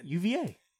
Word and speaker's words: UVA: Yeah, I'm going to take UAV here because Coach... – Did it again. – UVA. – You did said it wrong again.